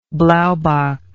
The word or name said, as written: blaubok